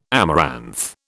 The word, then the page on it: amaranth: British English